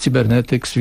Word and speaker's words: Cybernetics: Tak, firma wymawiana jest Cybernetics, więc się trzymajmy tej nazwy. Cybernetics Company.